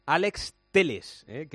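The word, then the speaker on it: Alex Telles: Bueno, en el Sevilla, uno de los nombres del día está al caer Alex Teles, que se dice así, me ha dicho Fernando Evangelio, que hay que decirlo bien, que brasileño es Alex Teles, aunque se escribe Telles, a ver si nos acostumbramos.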